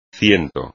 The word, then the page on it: ciento: espagnol